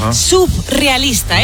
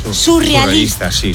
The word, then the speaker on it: surrealista: Y por otro lado, también decimos mal surrealista. Muchas veces la gente dice, y esto me pone muy nerviosa, subrealista. Es surrealista.